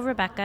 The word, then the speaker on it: Rebekka: You can follow me on Instagram or Twitter at Hello Rebecca, and my name is spelled R-E-B-E-K-K-A.